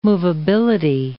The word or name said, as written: movability